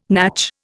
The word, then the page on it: natch: Indian